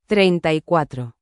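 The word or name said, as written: treinta y cuatro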